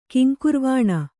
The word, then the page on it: ಕಿಂಕುರ್ವಾಣ: kiŋkurvāṇa